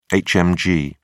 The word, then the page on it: hmg: British English